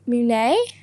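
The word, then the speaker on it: Mũi Né: Um, I went to a coastal city, um, Mui Ne. Yeah, is that how you say it? Yep.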